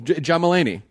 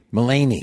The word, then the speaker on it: Mulaney: John Mulvaney. – John Mulaney. – Mulaney.